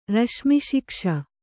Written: ರಶ್ಮಿಶಿಕ್ಷ

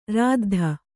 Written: ರುದ್ದು

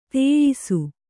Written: ದೋಯಿಸ

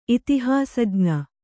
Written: ಇತಿಹಾಸಜ್ಞ

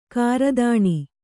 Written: ಗ್ರಥನ

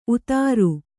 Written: ಊದರ

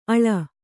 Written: ಅಳ್ಳು

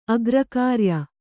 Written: ಅಗ್ರಕಾರ್ಯ

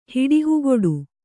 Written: ಹಿಡಿಹುಗೊಡು

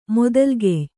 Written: ಮೊದಲ್ಗೆಯ್

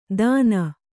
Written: ದಾನ

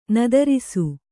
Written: ನಿತ್ತರಿಸು

